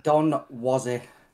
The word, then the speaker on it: Donwozi: Is it Donwozi or Donwozi? How do you say your name? I'm sorry. – It's Donwozi.